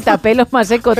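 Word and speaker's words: Thapelo Maseko: Y lo ha marcado Tapelo Maseko. – ¡Qué bien lo has dicho! No sé si se dirá así. – No, Tapelo Maseko, tal cual. Lo he leído tal cual se escribe.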